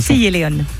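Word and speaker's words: Castilla y León: À quelle place Baptiste Vatic est-il arrivé le 26 juillet en Espagne lors de la Clásica Castilla et León? J'espère que je le dis bien, je ne suis pas sûr de la prononciation. – Castilla et León.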